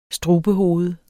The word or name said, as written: strubehoved